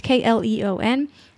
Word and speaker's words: Kleon: I'm probably saying that name wrong. It's Kleon.